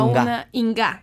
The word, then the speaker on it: Inga: Y una de mis favoritas en las canciones fue de, o sea, a lo mejor lo estoy pronunciando mal, pero Inca, que la cantaba Inga.